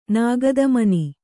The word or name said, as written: ನಾಗದಮನಿ